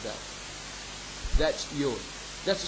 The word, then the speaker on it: yod: Now, the word jot in Hebrew is pronounced yod. And yod is the smallest letter in the Hebrew alphabet. Now, yod is equivalent to Y of English alphabet, ya sound.